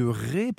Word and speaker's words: Retz: Alors ça s'écrit Retz, mais ça se prononce Ré.